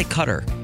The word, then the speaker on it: Qatar: However you pronounce it. Qatar. You said it right the first time. – Yeah, but some people say Qatar.